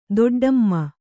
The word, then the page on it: ದೊಡ್ಡಿಮೆ: doḍḍime